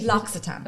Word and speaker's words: L'Occitane: L'Occitane, L'Occitane, L'Occitane. – I never know how to say it. – L'Occitane. – Okay. – It's a double, it's an X, a double C is an X in French.